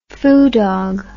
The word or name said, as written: foo dog